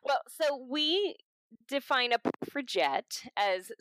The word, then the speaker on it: suffragette: So what does it mean to be a puffergate and how can someone become one? And did I pronounce that correctly? – Yeah. Okay. So, suffragette.